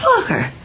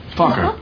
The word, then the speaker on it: Focker: And we were curious, how do you pronounce it? – Oh, just like it's spelled. F-O-C-K-E-R. – Fokker. – Fokker.